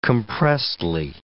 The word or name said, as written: compressedly